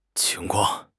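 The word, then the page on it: qing2 kuang4: tone 2; tone 4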